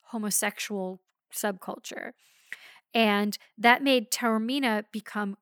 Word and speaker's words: Taormina: And he points out that the location is, it's shot in several places in Sicily, but the hotel is in Taormina. I don't know how to pronounce it. – Taormina, yeah.